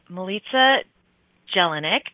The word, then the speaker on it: Milica Jelenic: Her name is Milica Jelenic.